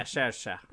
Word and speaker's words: Saoirse: I can't like Saoirse. – Yeah, Saoirse.